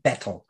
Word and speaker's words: betel: It's B-E-T-E-L. We pronounce it betel. I think some people pronounce it Bettle. So it's B-E-T-E-L.